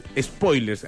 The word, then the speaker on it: spoiler: Spoilers, así con ES, spoiler con acento en la O, sin spoilers.